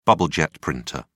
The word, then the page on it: bubble-jet printer: British English